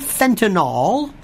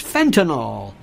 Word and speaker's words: fentanyl: It's not fentanyl. That will be F-E-N-T-A-N-O-L. It's F-E-N-T-A-N-Y-L. Fentanyl. Carboxyl. Windowsill. Kill. Pill. Jill. You got it? Fentanyl. It drives me nuts. Want to hear this? Fentanyl.